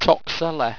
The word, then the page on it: toxela: owstwecian